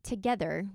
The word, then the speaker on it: TOGETHXR: So last week it was launched that a Together, which is spelled TOGETHXR, is a media company and commerce company designed to elevate women's voices around sports and beyond.